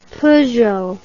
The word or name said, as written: Peugeot